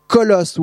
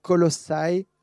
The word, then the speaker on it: Colossae: Donc Colosse, ou on devrait prononcer très justement Colossae, c'est une petite ville euh, qui est euh, dans la région de Phrygie.